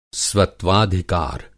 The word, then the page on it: स्वत्वाधिकार: Hindi